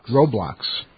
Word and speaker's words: Roblox: Now, companies including OpenAI, Roblox, I believe I said that right? – Roblox.